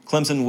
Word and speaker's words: Clemson: Clemson with an S, of course, even though I pronounce the Z.